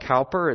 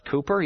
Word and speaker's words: Cowper: It looks like Cowper. It's pronounced Cooper.